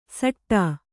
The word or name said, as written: ಸಟ್ಟಾ